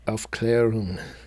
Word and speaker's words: Aufklärung: Aufklärung.